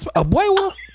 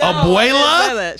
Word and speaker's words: Abuela: Grandma's Abuewa? And it's like, Abuewa? What the fuck is you, Elmer Fudd? What the fuck is Abuewa? No, Abuela? – Shut up.